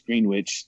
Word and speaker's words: Greenwich: I'm not trying to make anybody upset, but like I don't pronounce Greenwich. I like, you know, it's Greenwich. There's, you know, Greenwich is phonetically spelled completely different than, you know, the way that they say it.